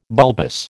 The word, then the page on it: bulbous: American English